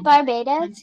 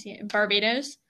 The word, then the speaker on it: Barbados: So, her birth information, she was born on February 20th, 1988, in St. Michael, Barbados. And I'm probably saying that wrong, but whatever. I'm sorry. – Isn't it Barbados? – Barbados? Okay, yeah, Barbados. We'll say that.